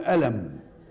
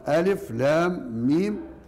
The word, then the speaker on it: الم: يبقى إذن حينما كتبت المصحف بهذه الصورة فهي مكتوبة بالحروف، ولكننا حينما ننطقها ننطقها بأسمائها، ننطقها بأسماء، لا نقول ألم، لا نقرأها ألم، وإنما نقرأها بأسمائها ألف لام ميم.